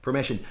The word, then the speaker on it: Promession: That's Promission. I think I'm probably saying that wrong. – Promission? – Promession? Promession? – Yeah. – Promession.